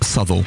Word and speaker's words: Southwell: Southwell is pronounced Southall.